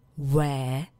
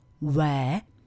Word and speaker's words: where: Co nám tam tak trochu dělá neplechu je, jak už to v angličtině ostatně bývá, výslovnost, která u toho prvního slova zní where, u druhého here a u toho posledního there. Where, here, there. Už dobře znáte to první tázací zájmeno. Where, kde.